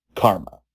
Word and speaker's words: CCRMA: or CCRMA, pronounced Karma.